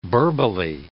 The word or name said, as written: burbly